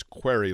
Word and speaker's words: Query: My last name is Querry, which at a very young age, I realize, is up for multiple pronunciations, and I have to occasionally remind people it's query like a question, K-W-A-I-R-Y, phonetically speaking.